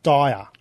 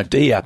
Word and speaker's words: Dia: Now, we were thinking, like, how, D-I-A. We think it's pronounced dire. – No, deer, not deer. – Dire. – Is it deer? – Deer, not dire.